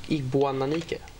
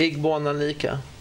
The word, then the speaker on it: Igboananike: Var det rätt uttalat? Ig Igboananike? Nästan. Hur, hur säger du? Igboananike.